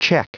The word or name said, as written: cheque